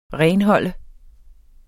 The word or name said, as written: renholde